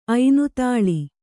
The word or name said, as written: ಐನುತಾಳಿ